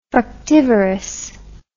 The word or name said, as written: fructivorous